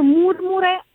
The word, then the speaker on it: Murmure: Questo Murmure, in verità il titolo si pronuncia in italiano. – Ah, ecco. – Bizzarro, perché tutto il disco è in inglese, quindi può trarre facilmente in inganno, però è così, alla latina.